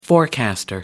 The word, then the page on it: forecaster: American English